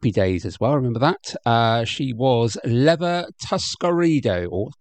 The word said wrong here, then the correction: Tuscadero: Tuscadero. There we go. Get it pronounced correctly.